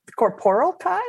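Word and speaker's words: Corporalki: So the corporal Kai, am I saying that right? Yeah, yes.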